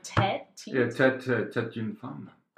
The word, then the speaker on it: tête d'une femme: How do you say that? Like tête d'une femme.